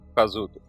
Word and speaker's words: Kazuto: Kazuto, né? Kazuto. Kazuto? Ah, tá, então tá certo. Então eu falei certo, então eu não sabia.